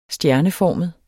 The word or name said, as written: stjerneformet